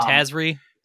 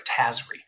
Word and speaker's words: Tazri: So the commander is General Tazari. Tazari? However you pronounce her name. – I've always pronounced it Tazri. – But... Tazri. Oh, I like that. I like that better. Tazri.